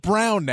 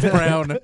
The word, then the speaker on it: Braun: I mean, other than pronouncing his own name, he's the best because it's pronounced Brown now. – Yep. – It's Brown. – With Michael. – Even though it's spelled Braun, we all know it's Brown.